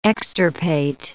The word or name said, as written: extirpate